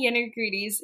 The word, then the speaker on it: Genagritis: Eleni Genagritis.